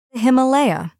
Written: Himalaya

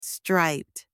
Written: striped

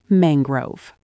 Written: mangrove

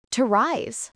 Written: to rise

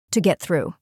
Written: to get through

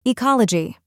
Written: ecology

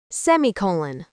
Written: semicolon

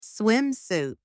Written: swimsuit

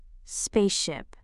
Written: spaceship

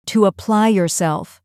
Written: to apply yourself

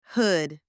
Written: hood

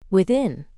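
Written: within

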